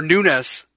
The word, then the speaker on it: Nunes: Like Devin Nunes, he's coming on, or Nunes is how you pronounce it, I guess.